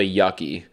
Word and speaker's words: yuccie: It's called a yucky. It's a term I just became familiar with today. It's spelled Y-U-C-C-I-E, Young Urban Creative Millennials.